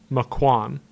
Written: Maquon

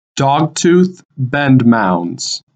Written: Dogtooth Bend Mounds

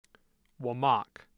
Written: Wamac

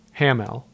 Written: Hamel

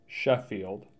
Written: Sheffield

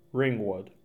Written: Ringwood

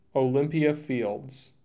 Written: Olympia Fields

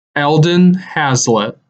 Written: Eldon Hazlet

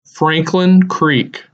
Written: Franklin Creek

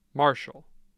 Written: Marshall

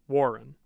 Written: Warren

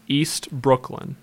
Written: East Brooklyn

